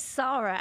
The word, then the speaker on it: Sara: So my first question is, do you pronounce it Sara or is that just the American way? Is it Sara? – It's Sara, actually, okay.